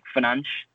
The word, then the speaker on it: Fernandes: So the Portuguese pronunciation is a little bit difficult because obviously in England, we, even with the surname, as English speakers, we're tempted to say as in Fernandes, as in like a three-syllable word. Whereas in Portuguese, they pronounce the Fernandes as like Fernanche. So they wouldn't really pronounce the Des.